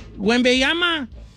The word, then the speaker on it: Wembanyama: Don Cheto, a ver, dígame, ¿cómo se dice? – Wembanyama. – Wembanyama. – Dile Wembanyama, como le dice todo el mundo.